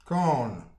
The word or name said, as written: kan